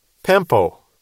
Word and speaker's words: pempo: Apostello is A-P-O-S-T-E-L-L-O. And you can put a line over that second O, that's a long O. Pempo is spelled P-E-M-P-O. Line over the O is a long O again.